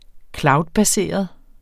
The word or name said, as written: cloudbaseret